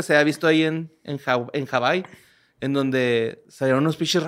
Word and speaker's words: Hawái: En realidad se pronuncia Hawái.